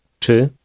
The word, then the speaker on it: czy: Czy? Czy? Czy? – This word begins with a sound which doesn't exist in English.